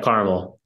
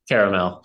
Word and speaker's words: Carmel: Yeah, Carmel. – Carmel, yeah. Okay. – Oddly enough, there's no A in between the R and the M, so it's Carmel. Not caramel. – Carmel, Indiana, which is really just kind of outskirts of Indianapolis.